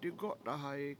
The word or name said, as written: digO7tahayi7